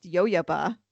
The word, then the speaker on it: jojoba: I feel bad because I didn't even know that jojoba was pronounced yo-yoba.